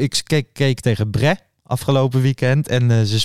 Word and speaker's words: Brest: Mm Bre zeg je denk ik. Brest is het geschreven.